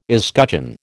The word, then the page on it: escutcheon: American English